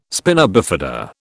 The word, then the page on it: spina bifida: British English